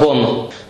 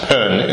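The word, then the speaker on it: Bön: А, например, вы слышите слово bon. – Well, it's pronounced pen in Tibetan, but Western people pronounce it bon, and this is the name of the pre-Buddhist tradition in Tibet.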